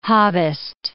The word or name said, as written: Hobbist